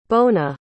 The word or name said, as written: bona